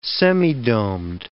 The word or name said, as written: semidomed